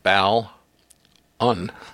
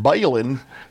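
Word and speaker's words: balun: Do you know the correct pronunciation? It is balin, right? – Well, it is a contraction of balanced to unbalanced. So, bal. Un. – So it's not balin.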